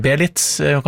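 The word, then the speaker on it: Beelitz: Vi var i Tyskland, i Belize. Belitz, kanskje riktig uttale.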